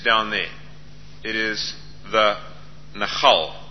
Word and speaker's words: Nachal: It is Nahal with a L on the end. L for Leonard. Nahal. And it's spelt N-A-C-H-A-L.